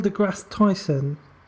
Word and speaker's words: deGrasse Tyson: I'm going to pronounce his name wrong, probably, but Neil deGrasse Tyson.